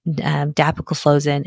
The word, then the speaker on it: dapagliflozin: And the ones that have been studied are canagliflozin, dapagliflozin, and empagliflozin. And the way I remember it is like CDE. Those are the ones that have been studied so far. Canagliflozin, dapagliflozin, and empagliflozin.